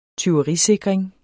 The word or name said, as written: tyverisikring